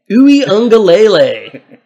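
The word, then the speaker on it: Uiagalelei: Ui Ungalele. Kind of sounds like the state fish of Hawaii.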